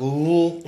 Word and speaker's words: Louisville: I can't pronounce Louisville correct. I always, I always do like Louisville. – It's Louisville.